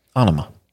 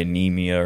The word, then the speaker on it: Anima: That's how you pronounce it? – Anima. – Anima. I've been calling it something else for years. – What have you been calling it? – Probably Anemia or Nema or something like that.